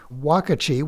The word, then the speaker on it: Uakti: the Brazilian group Wakati, which, by the way, is spelled U-A-K-T-I, and Third Coast Percussion?